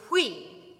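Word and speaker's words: Kyui: And my middle name is Kui.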